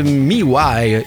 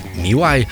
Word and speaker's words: MEWHY: Il s'appelle Miwai. Écoute, ça s'écrit M-E-W-H-Y. Miwai. Miwai. Moi, j'avais envie de le prononcer, mais oui. Mais non, c'est Miwai.